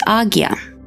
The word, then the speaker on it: Ajna: As we near the pinnacle of our journey, we arrive at the third eye chakra, or Ajna, pronounced as Agya.